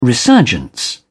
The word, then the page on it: resurgence: British English